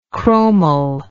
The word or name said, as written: chromel